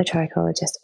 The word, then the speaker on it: trichologist: Also, actually, I just want to add, I started to use a trichologist, you know, like as you would sort of go to the dentist and have a checkup appointment. – Yeah. – If you can, it's great to see a trichologist.